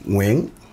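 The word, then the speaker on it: Nguyễn: Wing. Is how you say it in Vietnamese.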